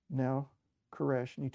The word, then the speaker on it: Koresh: And so Vernon must change his name to Koresh, because that is how the name Cyrus is pronounced in Hebrew.